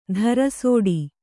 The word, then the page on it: ತಿರಸಟ: tirasaṭa